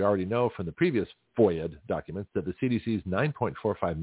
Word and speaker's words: FOIA: He says, Gutzkow, G-U-E-T-Z-K-O-W. Doesn't sound very Jewish, but that's okay. Gutzkow, who has secured numerous FOIA'd documents, that's F-O-I-A, they call it a FOIA, which is a pronunciation of Freedom of Information Act, F-O-I-A acronym.